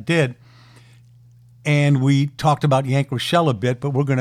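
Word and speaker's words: Yank Rachell: I've heard the name pronounced Yank Rachel, but the people who knew him called him Yank Rachel.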